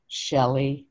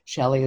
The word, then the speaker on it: Shelley: Well, I would suggest going to my Facebook page, Power of Woman Seminars with Shelley Negelow. Shelley is with an E-Y, S-H-E-L-L-E-Y. The last name is spelled N as in Nancy, E, G as in girl, E, L as in Larry, O-W.